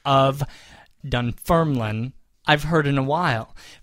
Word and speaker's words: Dunfermline: Now, he breaks it down here with asterisks and underscores and such, but it looks like Dunfermline. – Dunfermline. – Yes, emphasizing the second syllable.